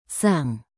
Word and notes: sang: indonesian

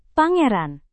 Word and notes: pangeran: Indonesian

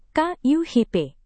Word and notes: kuhp: indonesian

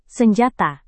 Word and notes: senjata: Indonesian

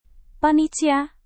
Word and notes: panitia: Indonesian

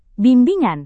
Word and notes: bimbingan: indonesian

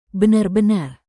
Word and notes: benar-benar: indonesian

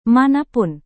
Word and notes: manapun: Indonesian

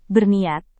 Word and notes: berniat: Indonesian

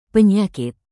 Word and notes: penyakit: indonesian

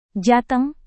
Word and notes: jateng: Indonesian

